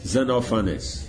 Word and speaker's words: Xenophanes: How did you say that? Xenophanes. Oh, I see. Just no emphasis. Xenophanes.